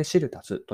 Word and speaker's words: SIRU+: 大文字で SIRU と書いて、足すというのは足し算のあのプラスの記号ですね。これでシルタスと読みます。